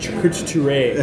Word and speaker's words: charcuterie: See, charcuterie, and I'm sure one of us is pronouncing this wrong. – No, it's... – You got it right. It was like charcuterie.